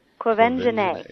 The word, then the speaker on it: Quvenzhané: It's Quvenzhané.